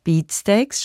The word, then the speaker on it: Beatsteaks: Beatsteaks spricht man so, Beatsteaks.